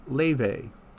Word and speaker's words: Laufey: And now we're taking that turn to romance, folks, with an LA-based jazz producer, vocalist, who goes by the name of Laele. Though it's spelled L-A-U-F-E-Y.